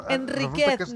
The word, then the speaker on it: Enríquez: Enríquez, no Enríquez. – S'ha de pronunciar Enríquez sense tilde.